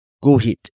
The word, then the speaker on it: guhit: Ipagguhit is spelled as I-P-A-G-G-U-H-I-T. It is a command to draw something for someone. Ipagguhit comes from the root word guhit. Guhit. Guhit, which is spelled as G-U-H-I-T.